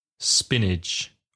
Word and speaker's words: spinach: Spinach Again, the pronunciation not reflecting the spelling, S-P-I-N-A-C-H, pronunciation, spinach. – Guisante. – P, P-E-A, P, or peas. – Not P-E-E?